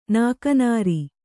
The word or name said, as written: ನಾಕನಾರಿ